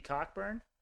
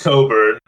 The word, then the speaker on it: Cockburn: Kofi Cockburn? – It's pronounced Coburn, but I wanted to see hear if you would say it that way.